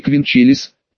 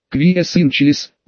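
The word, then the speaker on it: qui es in caelis: Шейла, не квинчелис. Прости Боже, нет. Квие сын челис. Ударение на е.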